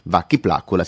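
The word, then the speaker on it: Vakki Plakkula: Vacchi Placcula si scrive con le K ovunque voi pensiate che debbano esserci le C. Vacchi Placcula.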